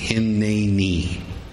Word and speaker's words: Hineni: If you want to spell it out with dashes in between, it would be H-I-N-N-A-Y with a little apostrophe over the top of that, emphasis on the middle syllable. N-N-E-E, Hineni.